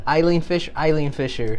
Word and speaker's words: Eileen Fisher: That is it Elon Fisher? – Elon Fisher. – Eileen Fisher.